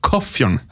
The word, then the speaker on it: Kåfjorden: så, hvor de sier stedsnavn, så ville jeg tenkt at dette uttales Koffjorden.